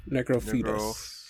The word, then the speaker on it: Necrophidius: I don't know if this is the correct way to pronounce this, but I think they're called Necrophetus? – Necrophetus? – Necrophetus? – It is that. – Necrophidius. – That sounds right. Yeah, a combination of dead and snake.